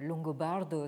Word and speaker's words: Longobardo: mais Longobardo, c'est-à-dire ça, s'écrit comme ça se prononce, L-O-N-G-O-B-A-R-D-O, donc euh, qui a vécu entre 1559 et 1654.